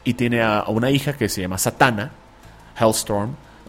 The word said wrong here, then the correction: Hellstrom: Hellstrom, siempre lo digo mal.